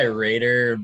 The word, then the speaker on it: Roeder: We go by Raider, but Raider.